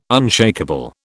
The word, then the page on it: unshakeable: British English